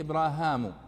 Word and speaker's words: إبراهيم: ثم يأتي بعده إبراهيم، إبراهيم بالكسر الهاء وليس بالياء، إبراهيم، وإبراهام، وإبراهم بالضم، ثم إبراهام، إبراهام. هذه ستة يعني ألفاظ ينطق بها اسم إبراهيم عليه الصلاة والسلام.